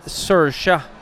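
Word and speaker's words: Saoirse: Men det är klart att Sersha, som jag tror hennes namn uttalas, Ronan, hon kommer ju inte vinna detta.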